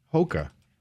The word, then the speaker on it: Hoka: They also have amazing pets available for adoption, like Hoka. Did I say it right? – So yes.